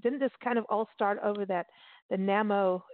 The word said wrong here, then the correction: NaNoWriMo: am I saying it right? NaMo? – NaNoWriMo. – NaNo. – NaNoWriMo. – Okay.